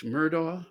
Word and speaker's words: Murdaugh: Well, it's Murdaugh, I guess you pronounce it. I've only heard it pronounced uh, every night on the news.